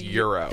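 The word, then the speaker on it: Gyro: How do you say Euro? – Euro. – I work at a Greek restaurant. It is Euro.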